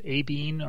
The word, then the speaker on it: Abine: I think they're actually pronounced Abine or Abine. A-B-I-N-E.